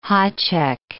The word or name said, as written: hacek